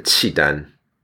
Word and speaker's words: Qidan: Or, as we pronounce the name in Mandarin, the Qidan, or in English, the Khitan.